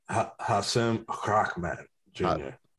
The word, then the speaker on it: Hasim Rahman Jr.: Hasim Rahman Jr. Thank you. Thank you for pronouncing it correctly.